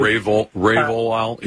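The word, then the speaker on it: Revoal: And then it turns out that the guy that she named to chair the OLCC, the commission, Marvin Ravoal, if I'm mispronouncing his name, I apologize. – Ravoal, yeah, it's R-E-V-O-A-L with a tilde, I think, above the E.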